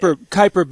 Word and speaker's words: Kuiper: It's like, Kuiper belt, Steve. Sorry. – Yeah. – That's how you correctly pronounce that word.